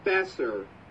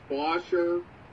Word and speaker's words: Boescher: No, Brock Besser. You've probably seen it as B-O-E-S-C-H-E-R and just assume that it's pronounced Bosher, but it's pronounced Besser. Besser.